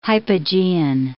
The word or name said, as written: hypogean